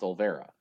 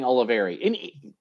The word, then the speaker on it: Olivera: But when I asked them, they were like, no, they told us it's pronounced Olivera. – Oh, okay. – So, but I was saying Oliveri.